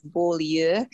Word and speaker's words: Beaulieu: The proper pronunciation is Beaulieu, but it doesn't exist in English, so I just say like bowl and then you.